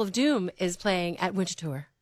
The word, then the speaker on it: Winterthur: And you have to say it like that. – Yeah. Winterthur.